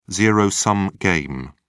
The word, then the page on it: zero-sum game: British English